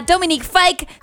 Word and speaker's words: Dominic Fike: Así que es momento de escuchar a Dominic Fike. Sí, así se pronuncia. Nos tomó años de dominar el arte de pronunciar Dominic Fike.